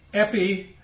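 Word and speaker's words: epidechomai: And the first word receive here is a word that's in your handout there, and it's pronounced epidekami. Okay? Epidekami. Literally, it means to accept beside.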